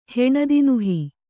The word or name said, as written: ಹೆಣದಿನುಹಿ